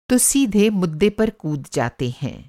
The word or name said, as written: मुद्दा